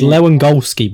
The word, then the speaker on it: Lewandowski: It's Lewandowski, boys.